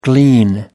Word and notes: glean: American English

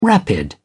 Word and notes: rapid: British English

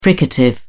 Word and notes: fricative: British English

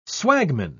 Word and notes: swagman: British English

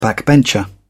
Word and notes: backbencher: British English

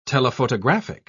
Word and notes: telephotographic: British English

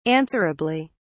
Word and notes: answerably: British English

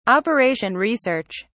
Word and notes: operation research: British English

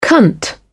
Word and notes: cunt: British English

